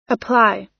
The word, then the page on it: apply: American English